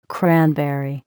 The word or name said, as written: cranberry